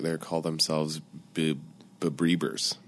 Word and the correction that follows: Beliebers: Oh, Beliebers.